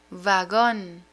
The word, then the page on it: vagon: Spanish